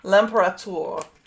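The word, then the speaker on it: L'Impérator: Am I saying it wrong? – Yes. It's okay. – The Imperator? – We'll put it in the show notes so that those of you who actually can read the French can read it. I believe it's L'Imperator.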